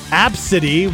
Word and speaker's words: Abcde: There was this woman flying from California to Texas on a Southwest flight with her daughter, whose name is Absidy, which is spelled A-B-C-D-E, and the gate agent made fun of the name and posted it on her social media.